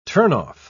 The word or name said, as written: turnoff